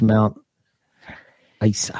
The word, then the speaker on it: Mount Isa: And there's Mount Isa.